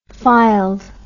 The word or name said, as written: fylde